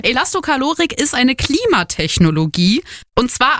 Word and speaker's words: Elastokalorik: Es geht um Elastokalorik. Habe ich das jetzt richtig gesagt? – Ja, bisschen langsam, aber richtig.